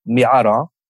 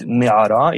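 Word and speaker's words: me'ara (מערה): For example, there's this word, me'ara, that comes from Hebrew. In Hebrew, that means cave. Me'ara, modern Hebrew. So it was pronunciated me'ara in Moroccan Jewish Arabic.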